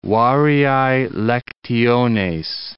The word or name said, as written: variae lectiones